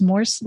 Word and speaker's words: Morse: And that is Morse like Morse code.